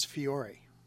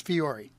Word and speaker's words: Fiore: It's spelled F-I-O-R-E. – I believe it's Fiore. – See, it could be Fiore. It could be Fiore. What do you say it is? – I think it's Fiore. – Fiore? Like Fury? – Well, that it could turn into that. – Wow. So the athletic director's last name was Fury. – Fiore, I do believe.